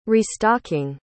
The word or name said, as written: restocking